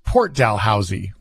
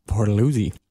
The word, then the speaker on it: Port Dalhousie: And then, but then when you go to Niagara, there is Port Dalhousie, which is not pronounced Dalhousie. – No. – How's it pronounced? – Port Dalhousie. – And then when you're a local, you don't pronounce it like that. It's all one word and it runs together. It's Port Dalhousie.